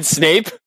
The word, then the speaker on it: Snape: You got to say it like Snipe, though. – Who the hell is Snipe? You mean Snape? – Same thing.